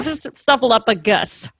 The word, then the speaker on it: Snuffleupagus: Mr. Snuffleupagus? Uh-huh. Snuffleupagus. Sorry. Uh-huh. I don't know why I said a k.